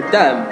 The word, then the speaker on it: dumb: 还有一个就是 dumb，就是那个 b 不发音，在这里头 b 不发音。